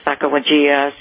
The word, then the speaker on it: Sacagawea: Her Sacagawea, and it's, we pronounce it Sacagawea. Some people pronounce it a little bit differently, but that's the spelling of it.